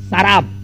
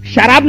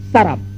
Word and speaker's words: سراب: میراج میں سراب، اس کو بعض لوگ سوراب پڑھتے، غلط ہے وہ. قرآن حکیم نے جو تلفظ بیان کیا، جو پروناؤنسیشن، وہی صحیح پروناؤنسیشن، سراب، شراب نہیں، سراب.